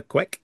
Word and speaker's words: Quek: three hosts are going to be Dermot O'Leary, OCU Manurora, and Samantha Quek. Is that how you pronounce her name? – Yes.